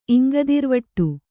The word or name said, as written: ಇಂಗದಿರ್ವಟ್ಟು